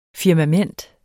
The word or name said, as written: firmament